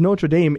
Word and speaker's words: Notre Dame: It's pronounced Notre Dame, Indiana, here in America.